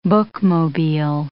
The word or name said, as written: bookmobile